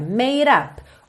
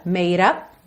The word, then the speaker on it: made up: Então, olha, made up, o E do made não é pronunciado, o D eu já posso juntar no up com rarara, made up, uhum.